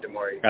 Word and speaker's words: DeMaurice: Is that his name? Am I saying it right? DeMaurice? – Yeah, DeMaurice.